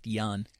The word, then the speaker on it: Ian: I will cut a mohawk into Ian Mahaney's head if that will get us rid of Gortat. – It's pronounced Jan.